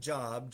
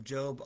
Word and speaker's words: Job: spelled like Job, J-O-B, but it's Job.